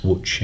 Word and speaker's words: Łódź: spelled L-O-D-Z, but pronounced Łódź in Poland.